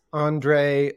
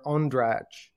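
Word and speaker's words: Ondřej: It's a similar question as whether it's Ondrej or Ondrej. I actually answer to both.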